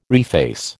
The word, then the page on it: reface: American English